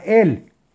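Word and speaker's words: él (אל): Aleph Lamed que se pronuncia él y dice la página así.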